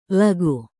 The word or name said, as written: lagu